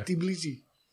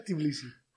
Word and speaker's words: Tbilisi: Tbilisi. – Ja, spreek je het zo uit? – Ja, Tbilisi.